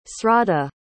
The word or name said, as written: sraddha